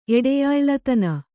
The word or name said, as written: ಎಡೆಯಾಯ್ಲತನ